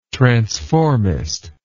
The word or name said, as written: transformist